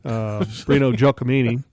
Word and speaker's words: Breno Giacomini: Breno Giacomini, who played for Seattle. I think I said that right, didn't I? – Sure. – Breno Giacomini.